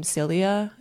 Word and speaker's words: Cilia: So short for my name, Celia, it's spelled C-I-L-I-A. So I would just go by L-I-A, Leah, because it feels like I'm stepping into a new embodiment of womaning, if that makes sense.